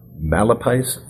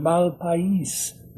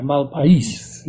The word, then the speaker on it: Malpais: A lot of Anglos call it the Malpai Review. And I say, no. It's got an S on it, Malpais. I always correct them. You know, the accent is on the I, Malpais.